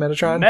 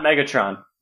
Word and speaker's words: Metatron: How do you say that guy's name, Metatron? – Metatron, yeah. – Metatron. – Not Megatron. Not Megatron. – It's not Megatron.